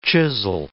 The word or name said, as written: chisel